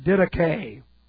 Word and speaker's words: didache: It is the word didache. Didache. And this is D-I-D-A-C-H long E.